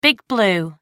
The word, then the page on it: big blue: American English